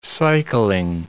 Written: cycling

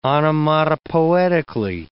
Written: onomatopoetically

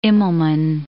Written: Immelmann

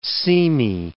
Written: seamy

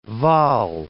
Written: Vaal

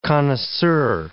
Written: connoisseur

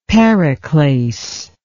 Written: periclase

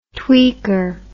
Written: tweaker

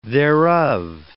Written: thereof

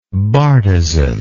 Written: bartizaned

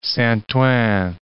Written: Saint-Ouen

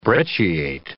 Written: brecciate

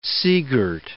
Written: seagirt

